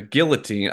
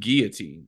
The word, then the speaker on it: guillotine: guillotine i always thought it was pronounced guillotine is it not or is it guillotine i think it's guillotine, guillotine. – it's french yeah, but like every every movie pronounces it guillotine and show.